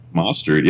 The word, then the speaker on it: Mostert: Mostert. Mostert. I hope I'm pronouncing that correctly. – Mostert.